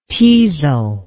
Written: teazle

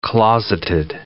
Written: closeted

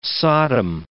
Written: Sodom

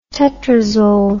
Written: tetrazole

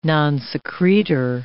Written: nonsecretor

